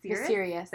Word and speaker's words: Viserys: It is Viserion? – Viserys. – There we go. – Viserys. Because it rhymes with Daenerys.